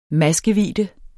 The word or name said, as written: maskevidde